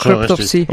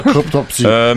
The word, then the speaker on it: Cryptopsy: Wir machen mit, jetzt spreche ich es bestimmt falsch aus, weil ich es immer falsch ausspreche, Cryptopsy. – Ja, das auch gesagt, Cryptopsy. – Okay. Cryptopsy.